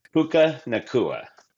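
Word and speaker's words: Puka Nacua: They got that guy, Puka Nuka or Naku, right? And I'm going to butcher his name every time. – Puka Nakua.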